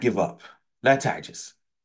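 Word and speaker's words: ta'jiz (تعجز): Wasta'in billah wa la ta'jiz. You'll hear a lot of people say this hadith, they say la ta'jiz, but that is an incorrect pronunciation according to many of the scholars of hadith.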